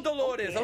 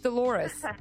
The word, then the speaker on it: Dolores: Oh, a white girl, Dolores. Okay. – Don't say it like that. She doesn't say her name like that, I bet. It's just Dolores.